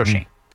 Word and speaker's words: Schoen: If you're going to criticize a general manager, just, you know, say his name right. Joe Shane.